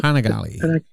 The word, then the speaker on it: Panigale: So, it's based on the Panigale, is that how you pronounce it? – Panigale. – Panigale, there you go.